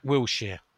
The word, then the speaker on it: Wilshere: Can pronounce Jack Wilshere okay? Or has he been practising? – Jack Wilshere. – Ah, I see. Well done.